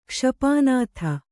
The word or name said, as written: ಕ್ಷಪಾನಾಥ